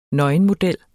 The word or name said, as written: nøgenmodel